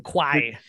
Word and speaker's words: Kwai: And as people that don't know who David Lean is, he directed these large scale epics, The Bridge on the River Kwai, am I saying it right? – Kwai, Dr. Zhivago, and Lawrence of Arabia.